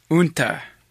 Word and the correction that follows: Uintah: Winta. – Winta. Winta County. – Winta County. – Winta? Is that what it is? How do you say it? – Winta.